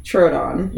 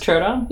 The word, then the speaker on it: Troodon: So how do you say it? – Troodon. – It's just Troodon? – Yeah, it's really easy.